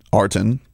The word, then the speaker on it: Artin: A-R-T-I-N, Arden. – Arden, yeah. – And then his last name is H-O-J-A-T.